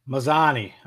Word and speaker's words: Mazzani: I'm going Manzini. What do you got here, babe? – Mazzani. – Yeah, Mazzani. It's like, excuse me. There's not an N before the Z.